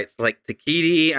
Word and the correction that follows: Taika Waititi: I can never say his name. Anybody? – Taika Waititi.